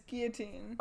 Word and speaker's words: Guillotine: It's Guillotine. – Oh, but it has the L, but it's pronounced. – Yeah, it's French. It's like...